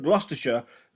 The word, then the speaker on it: Gloucestershire: And then they rented a place called Clearwell Castle in Gloucestershire. I hope I said that correctly, all my Brits. – Gloucestershire.